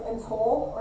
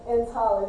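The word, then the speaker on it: entale (ἐντολή): I put the little pronunciation there because it looks like entole or entole, right? But I put entale, that's how you pronounce it in the Greek.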